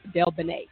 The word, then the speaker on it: DelBene: It is Suzan DelBene.